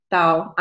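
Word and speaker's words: Dao (島): Dao, island, pronounced Tao in Taiwanese, is built from the relationship between earth and sky.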